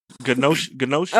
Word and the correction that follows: gnocchi: Oh, gnocchi. Gnocchi. Boy, gnocchi. – No, silent G. Silent G.